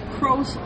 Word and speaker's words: Cros: Our first drive-by is Kros Linux, and that's spelled C-R-O-S Linux, formerly known as Chrome OS Linux.